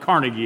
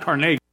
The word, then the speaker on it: Carnegie: Now, what he never thought about, when we understand the word Carnegie, many of us pronounce it Carnegie Hall, but it's actually pronounced Carnegie.